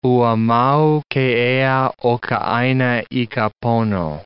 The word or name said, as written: ua mau ke ea o ka aina i ka pono